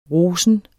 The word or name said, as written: rosen